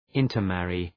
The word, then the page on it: intermarry: {,ıntər’mærı}